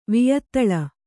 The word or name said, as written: ವಿಯತ್ತಳ